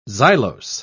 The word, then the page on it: xylose: British English